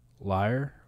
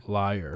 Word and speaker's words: lawyer: Lawyer. – Do you remember how they pronounce that in Tennessee? – Liar? – Lawyer. – Liar. – I do remember that.